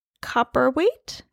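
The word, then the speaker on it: Cowperthwaite: It's directed by Gabriella Copperthwaite. – Yes. – Is that how you say it? – That sounds great.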